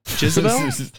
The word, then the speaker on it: Jezebel: Jezebel? That is a name? – Yeah, you said it right.